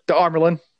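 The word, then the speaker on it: Amyrlin: The Armelin.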